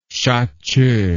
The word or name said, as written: shache